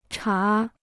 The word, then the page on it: 茶: chá